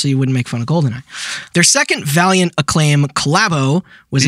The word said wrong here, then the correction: Valorant: You're pronouncing Valorant wrong.